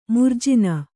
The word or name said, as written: ಮಾರ್ಜನಿ